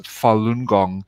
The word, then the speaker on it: Falun Gong: I came across a very ancient spiritual movement called Falun Gong, and it's pronounced Falun Gong,